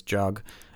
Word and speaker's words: Jug: I guess that's how you pronounce it. J U G. I think it's Jug.